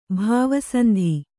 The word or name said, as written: ಭಾವಸಂಧಿ